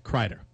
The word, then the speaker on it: Kreider: According to the NHL pronunciation guide, Kreider. – Okay, so I was right.